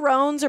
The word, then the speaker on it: macaron: I think it's pronounced macaroon. It's macaron. Because macaroons are the coconut things. – Oh. – Macarons are like the French ones.